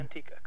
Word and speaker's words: Manteca: But do you have outdoor homeless meals in Manteca? Is it Manteca we're talking? I got the right, I got the name right there? – Manteca, correct. Yeah. Spanish for lard.